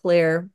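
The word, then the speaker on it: Xlear: I still say every baby should be put down with X Clear, which is pronounced clear, but you can get it in the America Out Loud store.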